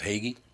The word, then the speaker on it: Hagey: Your last name pronounced Hagey? – Hagey, that's right.